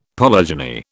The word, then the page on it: polygeny: British English